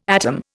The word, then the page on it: atom: Indian